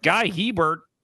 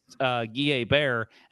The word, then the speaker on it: Hebert: And so when he was looking at the roster, he was like, Guy Hebert. And they were like, no, it's actually pronounced Guy Hebert.